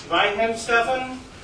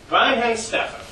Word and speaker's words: Weihenstephan: Weihenstephan. The thing about German words is you got to pronounce every letter. Yeah.